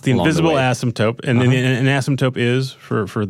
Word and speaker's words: asymptote: This is, I had to Google up what an asymptote is. Am I pronouncing it correctly? – You are.